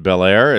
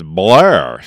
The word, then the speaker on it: Bel Air: I have a friend from Bel Air, and he would tell me, it's not pronounced Bel Air, it's Blair.